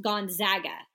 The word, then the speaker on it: Gonzaga: It's Gonzaga.